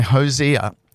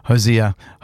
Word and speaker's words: Hozier: Now, this next tune by Hozier, I didn't pronounce that right. Don't worry about it. Hozier.